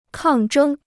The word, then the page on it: 抗争: kàng zhēng